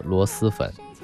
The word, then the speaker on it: 螺蛳粉: 哎，那个字念丝，螺蛳粉，写的是一个虫子旁一个诗，但是他念丝。